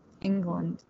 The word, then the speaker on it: Englund: I feel like I always pronounce people's name wrong. England. – I think that's right.